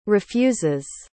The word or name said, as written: refuses